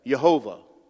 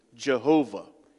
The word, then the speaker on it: Jehovah: you have Yehovah, or we pronounce it Jehovah.